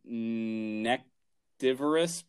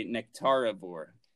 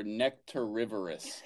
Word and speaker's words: nectarivorous: We looked up, like I said, nectivorous, but it's not. That's not how you say it. You say nectarivore or nectarivorous.